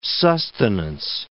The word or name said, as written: sustenance